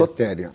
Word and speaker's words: Soteria: e traz a experiência de soteria, como se pronuncia? – Sotéria. – Sotéria,